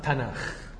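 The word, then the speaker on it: تناخ (תנך): الكاف دي بقى ساعات لما بتيجي في اخر الكلمة على حسب، ممكن تتنطق كاف او ممكن تتنطق خ، واضح ان هما هنا كانوا بينطقوا خ، فيبقى لما اخد التاف مع النون مع الكاف تبقى هم ينطقوها تناخ.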